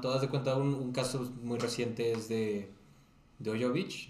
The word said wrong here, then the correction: Djokovic: no sé si se dice así. El tenista. Sí, sí, sí. Djokovic. Djokovic. Gracias.